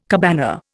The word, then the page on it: cabana: Indian